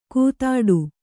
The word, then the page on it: ಕೂತಾಡು: kūtāḍu